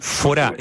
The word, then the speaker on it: Forá: Estamos hablando del Forá. F-O-R-A con acento en la A.